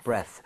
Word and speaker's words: breath: 그래서 발음할 때 breathe 이게 아니라 breath. breath.